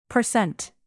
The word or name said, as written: percent